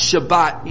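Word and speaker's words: Shabbat (שבת): Now, the Hebrew word Sabbath is pronounced Shabbat in Hebrew.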